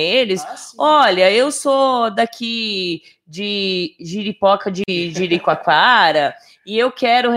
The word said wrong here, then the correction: Jericoacoara: Ah, Jericoacoara eu conheço.